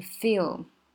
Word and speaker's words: feel: 然后呢，嗯，这个地方有一点要讲的就是这个 feel，千万千万不要卷舌读成 feel。这个很常见，对不对？就是你的舌头要放轻松，不要紧张，不要做太多的功，放轻松就好。